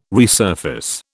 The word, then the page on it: resurface: British English